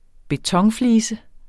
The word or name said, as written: betonflise